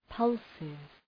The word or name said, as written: pulses